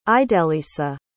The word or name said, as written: idelisa